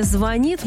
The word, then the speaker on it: звонит: В будущем ударение в слове «звонит» может измениться и переместиться на первый слог.